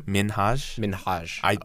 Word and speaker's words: Minhaj: How do you say Minhaj? His last name? – Minhaj. I believe that's how he pronounces it.